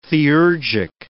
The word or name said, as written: theurgic